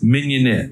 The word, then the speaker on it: mignonette: I'm not going to say it because if I say it, I'm going to say it wrong. But actually, it's a topping. What do you mignonette. – Have? Mignonette. – Mignonette. – Okay, I got it right.